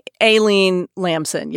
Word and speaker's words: Aileen Lamson: Aileen Lamson? – Aileen Lamson, yes.